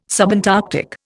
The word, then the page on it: subantarctic: Indian